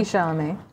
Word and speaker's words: Chalamet: Timothy Chalamet? How do you pronounce his name? – Timothy Chalamet.